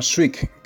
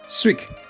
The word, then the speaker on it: Sweek: Hay otra opción que se llama Swig, que se escribe S-W-E-E-K, Swig, que es una plataforma para publicar historias y cuentos.